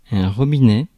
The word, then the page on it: robinet: [ʁɔ.bi.nɛ]; /ʁɔ.bi.nɛ/; /ʀɔbinɛ/